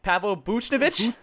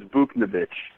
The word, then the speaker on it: Buchnevich: Pavel Buchnevich? Did I nail it? – Yeah, I mean, I've always thought it's Buchnevich. – Okay. So, the Russian pronunciation is a little harder. I watched the video where they called his name out, and I could never try that, so I'm going to give it Buchnevich for now.